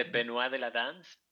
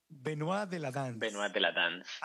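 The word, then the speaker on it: Benois de la Danse: ¿Cómo se dice? – Se dice Benoit de la danza. – Benoit de la danza.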